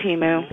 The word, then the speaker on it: Temu: Yeah, Temu or Temu. – It's Temu.